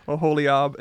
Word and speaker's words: Oholiab: Oholiab and Bezalel. – Ooh, good pronunciation. – I've been trying. Oholiab and Bezalel.